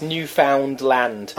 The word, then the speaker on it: Newfoundland: And you say, oh yes, Labrador and Newfoundland and that sort of stuff. – I think it's pronounced Newfoundland. I think that's how they like to pronounce it. Really emphasise the found.